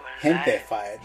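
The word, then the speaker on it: Gentefied: Hentified. Like, gente is like my people. My people. – Okay. – But they've also, it's a play on words, like gente and gentrified.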